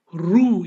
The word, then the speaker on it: roux: Roux is pronounced as roux,